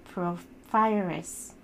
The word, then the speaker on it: porphyrias: Pyro and pyrouria belongs to a group of disorder called porphyrias. And I didn't pronounce it correctly, but P-O-R-P-H-Y-R-I-A-S. Porphyrias.